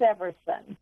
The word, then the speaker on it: Everson: And it is Everson.